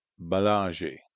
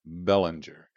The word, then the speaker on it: Belanger: Mary E. Bellanger, or Bellanger, it's spelled B-E-L-A-N-G-E-R.